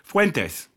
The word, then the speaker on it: Fuentes: Kanye West went on the Alex Jones show with, how are we pronouncing his last name? Nick? – Fuentes. – Fuentes.